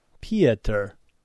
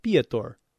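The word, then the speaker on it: Piotr: Pieter. Okay, I never knew how to pronounce that, because it's P-I-O-T-R.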